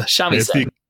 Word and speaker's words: shamisen: A giant wave, and I mean giant, threatens the small boat that she is on until she strums the strings on her shamisen.